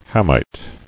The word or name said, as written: Hamite